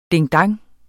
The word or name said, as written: ding-dong